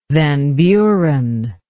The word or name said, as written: van buren